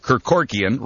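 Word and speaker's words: Kerkorian: Billionaire investor Kirk Kerkorkian, rhymes with Kevorkian, has told the company it needs to do three things.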